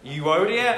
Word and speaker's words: Euodia: I entreat Euodia, and I entreat Syntyche. And let's just kind of agree in this room, that's what they're called and that's how they're pronounced, okay? Euodia and Syntyche, to agree in the Lord.